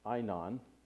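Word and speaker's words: Aenon: Now, John also was baptizing in, and this is pronounced Einon in the Greek,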